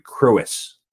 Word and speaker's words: Crous: His name is Gregory, let me see if I pronounce this correctly, Gregory Crous, right? – Crous, but yes, very well done. – Awesome. Gregory Crous, welcome to the show today.